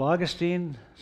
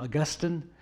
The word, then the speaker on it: Augustine: Some of you have heard of Augustine. Some people pronounce him Augustine.